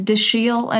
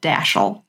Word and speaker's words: Dashiell: It is Dashiell Hammett, contrary to the common pronunciation of Dashiell, and it comes from his mother's maiden name, and her family was French, so it is pronounced Dashiell, and apparently he hated it when people pronounced it Dashiell.